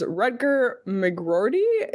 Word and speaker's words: Rutger McGroarty: This draft has some great names, and one of them is Rutger McGroarty.